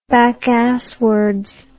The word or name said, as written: backasswards